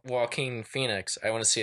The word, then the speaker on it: Joaquin: She's like, Joaquin Phoenix. And I was like, she's like, I'm saying this wrong, aren't I? I said, absolutely. – Yeah. – That would be Joaquin Phoenix, but I'd be very interested to see what Joaquin Phoenix looked like.